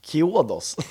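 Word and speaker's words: Chiodos: Eh, Craig Owens, eh, som är känd från bland annat eh, Shuidos. Kiodos. Kiodos, så uttalar han det.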